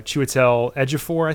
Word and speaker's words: Chiwetel Ejiofor: I think we should probably bring up uh, Chiwetel Ejiofor.